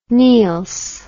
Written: Niels

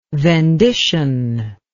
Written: vendition

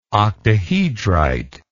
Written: octahedrite

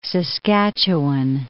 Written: Saskatchewan

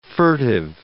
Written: furtive

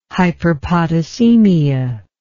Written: hyperpotassemia

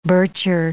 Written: Bircher

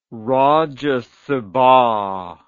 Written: rajya sabha

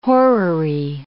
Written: horary

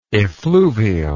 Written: effluvial

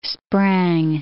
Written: sprang

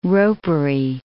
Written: ropery

